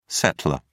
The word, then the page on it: settlor: British English